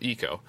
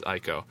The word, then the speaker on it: Ico: And then for the last one, remaster, I put Eco, also sometimes pronounced as Ico.